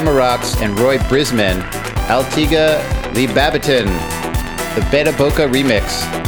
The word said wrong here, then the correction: Beta Blocka: Oh, sorry, that was the Beta Blocka remix on Restart Digital.